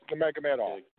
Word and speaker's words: Nurmagomedov: I can't pronounce Nemega it. – Medoff. It's Nurmagomedov. Nurmagomedov. That's what it is.